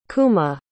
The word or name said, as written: Kuma